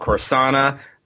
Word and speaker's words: Corsana: It's called Corsana, the Phalanx Syndicate, and I am pronouncing that way because everyone pronounces it wrong.